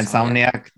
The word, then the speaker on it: Insomniac: And, you know, it just really gives me hope for the future, for what Insomniac. Is that how you pronounce it? – Yeah, Insomniac.